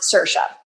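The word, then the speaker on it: Saoirse: Uh, and it's pronounced Sersha.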